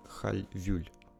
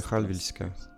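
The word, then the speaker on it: Hallwylska: Дальше мы поедем после прекрасного Ридер Хольмена, мы выйдем из него и просто прогуляемся совсем недалеко чтобы попасть в музей Хальвюль. Да, я так его произношусь, произношу, потому что так его произнести логичнее, так это произносится как бы с транскрипцией. По-шведски это Хальвильска музей.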